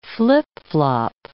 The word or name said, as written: flip-flop